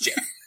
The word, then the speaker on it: GIF: Inventor says it's pronounced jif.